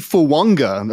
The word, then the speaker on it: Fuaga: we've been calling him a Talisi Fuanga, but apparently it's pronounced Talisi Fuanga. Apparently there's an N in there as well.